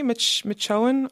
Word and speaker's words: Machoian: Am I saying that right, Stewie? Machoian? I hope I'm pronouncing that correctly. – I believe you are.